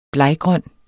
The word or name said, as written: bleggrøn